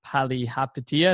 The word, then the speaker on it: Palihapitiya: And I know that one of the things you said is being selfish on your interview with billionaire Chamath Palihapitiya.